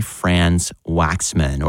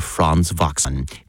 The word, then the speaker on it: Franz Waxman: by Franz Waxman, or Franz Waxman, if you want to use the pronunciation he would have been born with, you know, over in Europe.